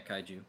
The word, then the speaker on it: kaiju: I'm not, like, the greatest judge for kaiju movies because, is that how you pronounce it? – Yeah, kaiju. – Kaiju, yeah, because, like, I don't really like them, typically.